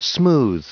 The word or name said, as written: smooth